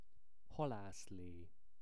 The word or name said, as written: halaszle